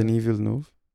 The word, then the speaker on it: Denis Villeneuve: Denis Villeneuve.